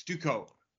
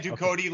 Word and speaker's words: Ducote: Women's strawweight, Angela Hill versus Emily Ducote. Or is it Ducote? I always say your name wrong. – It's Ducote. I said Ducote last time and I was wrong. – Yeah, because it sounds French, but she's not.